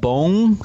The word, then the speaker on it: Bong: Is it Bong Joon-ho? – Bong Joon-ho. – Okay, Bong Joon-ho. – It's more like a O sound, Bong.